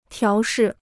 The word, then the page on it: 调试: tiáo shì